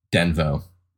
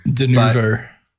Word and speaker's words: Denuvo: But there is a couple different possible fixes in like the BIOS settings and stuff like that that wasn't necessarily because of Denvo or whatever. – Denuvo.